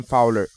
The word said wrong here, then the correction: Fowley: Fowley. I'm sorry. Fowley.